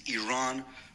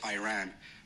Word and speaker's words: Iran: Our country is named Iran, not Iran.